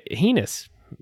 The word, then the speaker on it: heinous: And this is why sexual abuse on children who have brand spanking new dopamine receptors all ready to be programmed is so heinous. How do you say that word? – Heinous.